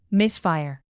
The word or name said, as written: misfire